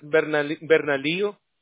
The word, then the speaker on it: Bernalillo: County yep. – is Bernalillo? – Yep, very good pronunciation, yes.